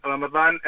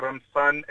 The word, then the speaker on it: റമദാൻ: നമ്മൾ റമദാൻ എന്നാണ് അറബിയിൽ ഉച്ചാരണം ചെയ്യുന്നത്. അത് മലയാളത്തിൽ റമദാൻ എന്നും എഴുതും, വളരെ പോപ്പുലർ ആയിട്ട് മലയാളം പത്രങ്ങളിലൊക്കെ റംസാൻ എന്നും എഴുതാറുണ്ട്.